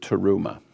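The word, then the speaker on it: teruma (תרומה): Before I go any further, we got to do a little more of a spin on this word teruma. Teruma, which means contribution, there's another word which is very, very close in the pronunciation of this word. It's teruah. Teruah versus teruma. Very, very slight difference.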